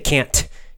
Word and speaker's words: can't: Say can't, get that little T, you know, just make it really strong.